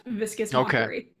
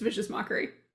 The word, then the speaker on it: Vicious Mockery: It is, in fact, Viscous Mockery. – Okay. Viscous? – I was just waiting to mispronounce it because I think it's funny. Yes, it's Vicious Mockery.